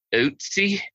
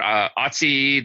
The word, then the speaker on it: Ötzi: Instagram page and who has written extensively on I think it's technically pronounced Ötzi. – Okay. – But I'm not good at the umlaut, but I try to get it right. But Ötzi, the 5,300-year-old Tyrolean man, is one of my favorite examples of, and in fact, the oldest example of the antiquity of tattooing.